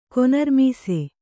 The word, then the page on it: ಕೊನರ್ಮೀಸೆ: Kannada